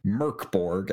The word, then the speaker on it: Mörk Borg: So M, diacritic, R-K, B-O-R-G. And it's not pronounced Mörk Borg. It's actually pronounced Mörk Borg, as I learned, 20 minutes ago.